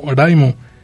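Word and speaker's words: Raimu (ライム): Lo curioso es que la palabra Raimu y Lime en inglés y en japonés se pronuncian igual, como Raimu.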